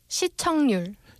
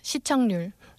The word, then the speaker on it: 시청률: Do you align that 응 with the 릴? How do you pronounce it again? – 시청률. – 시청률. So I don't have to go to the effort of saying 시청률. – Right. – 시청률. – Yes, 시청률.